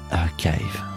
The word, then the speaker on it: Archive: Archive. Ouais. Je savais que j'allais mal le prononcer. – Ouais, tu l'as très bien. dit.